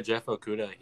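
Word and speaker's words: Okudah: Yeah, Jeff Okuda.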